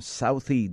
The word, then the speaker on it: Southie: I'm Southie Jack. That's Southie Jack. And I have to spell it because sometimes when I say it, they say selfie, like a selfie.